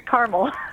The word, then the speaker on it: Carmel: It's Carmel.